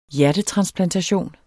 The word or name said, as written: hjertetransplantation